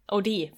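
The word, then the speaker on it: O'Dea: Judith O'Deal. O'Dee, förlåt. Judith O'Dee.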